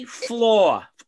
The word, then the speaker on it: Flaa: J Floor, or spelled FLAA. Fla pronounces floor, an Orioles pitcher.